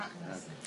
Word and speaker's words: hakmesser: And then he says, even the Mishnah Berurah, who says, don't use this hakmesser. Did I say that right? – Yeah, hakmesser.